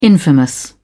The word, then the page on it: infamous: British English